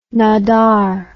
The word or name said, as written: Nadar